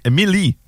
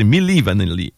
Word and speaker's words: Milli Vanilli: Comme M-I-N-I? – Ouais, oui, c'est vraiment M-I... non, Milli. Excuse-moi, je l'ai mal prononcé. Milli Vanilli. M-I-L-L-I. Puis écrit V-A-N-I.